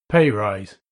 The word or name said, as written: pay rise